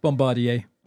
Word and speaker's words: Bombardier: Bombardier.